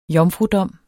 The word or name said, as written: jomfrudom